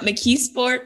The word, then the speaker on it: McKeesport: Growing up in, you got to tell me how to correctly pronounce McKeesport. Is that how you say it? – Yep, McKeesport, PA.